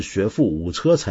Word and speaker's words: 学富五车: 说到不认识字啊，那么有位朋友叫 TONGSH，那么上回呢就很多朋友也跟你一样纠正我的读音了，因为我上回念一个成语，我讲学富五车，大家都说是学富五车才对。